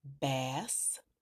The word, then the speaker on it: bass: Both are spelled B-A-S-S. One is a fish, and that's pronounced with the short A, A sound, bass, bass. And one is a deep or low tone, pronounced with the long A sound, bass, bass. Another example is B-O-W. B-O-W, pronounced with a short O sound, means to bend at the waist, bow.